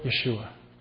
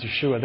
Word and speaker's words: Yeshua: But it's interesting for us to understand that we say Jesus and Joshua, but in Hebrew, the names are both Yeshua. Hebrew, the way you pronounce them is Yeshua,